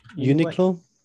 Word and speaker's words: Uniqlo: Uniqlo.